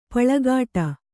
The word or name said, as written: ಪಲ್ಕಡಿ